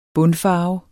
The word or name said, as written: bundfarve